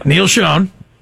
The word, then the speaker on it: Neil Schon: Okay, it rhymes with Deal On. – Oh, really? – Neil Schon.